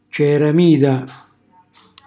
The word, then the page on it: ceramida: calabrese